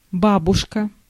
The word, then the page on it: grandmother: [ˈɡræn(d)ˌmʌðɚ]